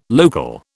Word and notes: local: British English

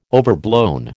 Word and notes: overblown: American English

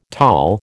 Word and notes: tall: American English

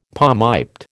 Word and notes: palmiped: American English

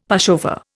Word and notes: pushover: Indian

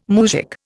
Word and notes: moujik: Indian

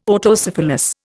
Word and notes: Autocephalous: Indian

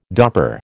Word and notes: Dopper: American English

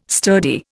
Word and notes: sturdy: Indian

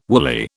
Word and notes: woolly: British English

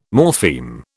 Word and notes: morpheme: British English

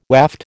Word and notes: waft: American English

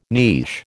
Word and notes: niche: American English

